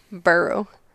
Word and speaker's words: Murfreesboro: Burrow. – But it's spelled O-R-O. – O-R-O. Burrow.